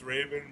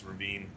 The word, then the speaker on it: Ravin: It's pronounced Raven, but, you know, for poetic license, it's Ravine.